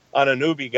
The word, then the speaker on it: Anunoby: After Valanciunas, I mean, I know they have the Anunoby guy, if I'm saying his name correctly. – Yeah, you nailed that one.